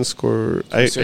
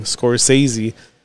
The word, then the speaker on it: Scorsese: Martin Scorsese. I can never say his name. Scorsese.